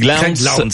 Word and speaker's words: Lowndes: Alors, faut le prononcer. Craig Lowndes. – L-O-W-N-D-E-S, ouais.